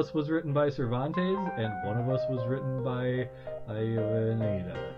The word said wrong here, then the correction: Avellaneda: What is the correct pronunciation? – Avellaneda.